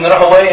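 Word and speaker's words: Rahwayh: Actually, from Lum al-Hadith, or the scholars of Hadith, his name is pronounced sometimes Rahway and sometimes Ruhuya. Most of the time, the people of Hadith, they pronounce it Rahuya, because Huwai in Persian means the devil, Shaitan. So they don't like to call him Rahuya, although it's probably actually his name.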